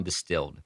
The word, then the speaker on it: DSTLD: Then I found Distilled. DSTLD, pronounced Distilled, offers premium denim and essentials at an affordable price.